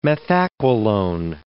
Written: methaqualone

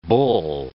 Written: bull

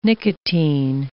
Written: nicotine

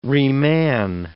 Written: reman

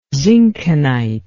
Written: zinckenite

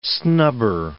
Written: snubber

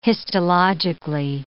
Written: histologically

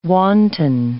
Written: wanton

